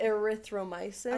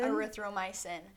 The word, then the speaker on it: erythromycin: Erythromycin. – Erythromycin.